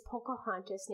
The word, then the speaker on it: Pocahontas: I double checked. It is Pocahontas, named after the person.